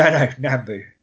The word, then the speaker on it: Nambu: I thought you just mispronounced bamboo. Sorry. – No, Nambu.